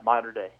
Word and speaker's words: Mater Dei: so it's is it mo- Mater Dei? Is that you say it Mater Dei Catholic? – Yeah, Mater Dei.